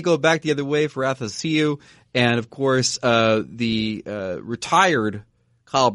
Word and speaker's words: Athanasiou: because he reminded me it's pronounced happy to see you. – That's right. Athanasiou.